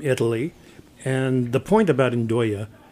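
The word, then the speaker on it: nduja: And I'm sorry, there's something I don't recognize, and I'm going to butcher it. Nudja? – Ndoya. – Ndoya. Nailed it.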